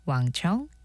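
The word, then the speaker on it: Wang Chung: 신혜림의 골든디스크 오늘의 첫 곡은 왕청 Everybody Have Fun Tonight이었습니다. 김하은님 신청곡이었어요. 들으셨나요? 음악 들으시면서 Everybody Have Fun Tonight 그리고 Everybody Have 왕청 Tonight 왕에 좀 더 가깝게 발음을 하는데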